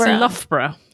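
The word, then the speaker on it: Loughborough: It's Lockbro. – It's Loughbro. Loughbro.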